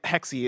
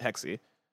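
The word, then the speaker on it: Hexe: Somebody in chat said, bless the, I think it was WB Buck in chat, said Assassin's Creed Hexy is pronounced Hex, like a witch thing. And that's what I thought. I originally was pronouncing it Hex, but I talked to somebody from Ubisoft and they were pronouncing it Hexy. And so now I'm pronouncing it Hexy.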